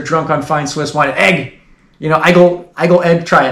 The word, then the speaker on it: Aigle: In the Switzerland porn, they show the city of well, they pronounce it Aigle, A-I-G-L-E, and I've been there, and everyone just, it's A-I-G-L-E, but everyone's like, oh, it's pronounced egg, like scrambled eggs or whatever. They say it's pronounced egg.